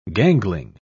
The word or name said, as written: gangling